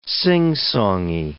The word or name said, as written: singsongy